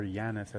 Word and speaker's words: Janus: That's funny because Janus is, or Yanis as it's pronounced, is one of the organizations in James Bond.